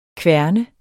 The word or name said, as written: kværne